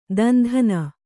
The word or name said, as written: ದಂಧನೆ